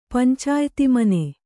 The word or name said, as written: ಪಂಚಾಯ್ತಿಮನೆ